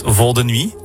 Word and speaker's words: Vol de Nuit: And also, 20 years ago, you made Vol de Nuit together. – Yeah, I hope I pronounced it in the right way. – Perfect.